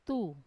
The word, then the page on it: tu: Wamesa